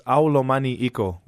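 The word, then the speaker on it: Au lomani iko: It's pronounced Aulomani Iko, which means I love you in English.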